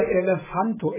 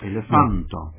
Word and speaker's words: elefanto: Der Akzent fällt immer auf die vorletzte Silbe. – Na, das ist ja ein Ding. – Ja, oder auch gesagt, die Betonung. – Ja. Die Betonung. Ja. – Bei Kato, also das A betont, weil das die Kato. – Vorletzte ist. Oder bei Elefanto ebenfalls, das A, weil es auch die vorletzte ist.